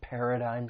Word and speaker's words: paradigm: So the third syllable has a silent G, and the vowel sound, it's a long I sound, not a short vowel sound. Paradigm. Paradigm.